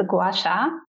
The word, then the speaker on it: Gua Sha: The Gua Sha. Oh, you can order that online. – Okay. – It's you spell it G-U-A-S-H-A.